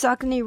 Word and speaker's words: Saucony: So did I pronounce Saucony right? – You got Saucony right, which is incredible.